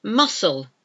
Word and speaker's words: muscle: Muscle. Muscles of your body. It's spelled M U S C, silent C, L, E.